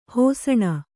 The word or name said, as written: ಹಿಸುಣ